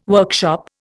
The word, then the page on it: workshop: Indian